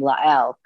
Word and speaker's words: Lael: Most of my friends were Jewish and they would always pronounce my name Lael because that's the Hebrew.